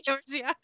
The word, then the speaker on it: Eorzea: There was is that how you say it? Did I say it right that time? – That is correct. – Eorzea.